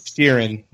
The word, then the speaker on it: Sheeran: So did I pronounce his name right? I don't even know. Sheeran. Sheeran. – I think that's Sheeran. Yeah.